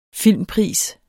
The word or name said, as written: filmpris